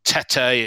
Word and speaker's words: Tete: Uh, Tete is how you pronounce his name.